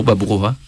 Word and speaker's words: Baburova: Alors, j'arrive pas à la prononcer correctement à la russe. Baburova. – Bravo, Baburova. – Baburova, voilà.